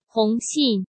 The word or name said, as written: Hong Xin